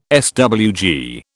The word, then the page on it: SWG: British English